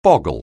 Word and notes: boggle: anglais

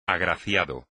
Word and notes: agraciado: espagnol

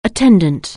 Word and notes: attendant: anglais

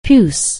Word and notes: puce: anglais

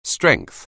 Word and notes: strength: anglais

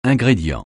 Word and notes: ingrédient: francais

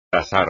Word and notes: tasar: espagnol

